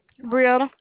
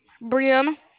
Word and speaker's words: Breanna: Brianna. – What's your name, honey? – Brianna. – I'm sorry, can you spell that? – B-R-E-A-N-N-A. – B-R-E-A-N-N-A? – Yes, ma'am.